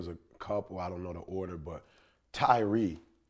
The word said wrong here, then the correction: Tyrese: Am I saying that right, Tyree? – Tyrese Gibson. Yeah.